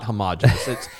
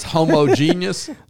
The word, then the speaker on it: homogeneous: It's not homogenous. – It's homogeneous.